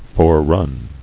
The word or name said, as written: Foreran